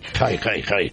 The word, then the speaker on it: Hay: Quiero saber también cómo se dice, si se dice high o ay. – High. Queda muy bien, que tú eres gitano, además. Ay. Hi. Yo toda la vida lo he dicho high en radio, vamos. – Vale, es que se escribe H-A-Y.